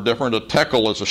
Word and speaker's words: tekel: Verse 27, Daniel pronounces the next word, tekel. Tekel. Pronounced tekel, the word is a noun and it means shekel.